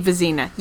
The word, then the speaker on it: Vezina: It looks like Vezina, and it should be Vezina.